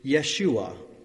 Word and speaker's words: Yeshua: But instead of saying Joshua each time it occurs, let's instead simply pronounce it in Hebrew, Yeshua, which is Joshua in the Old Testament and Jesus in the New Testament.